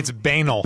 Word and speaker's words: banal: Did I say that right, by the way? Banal, B-A-N-A. Yeah. It's banal.